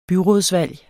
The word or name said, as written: byrådsvalg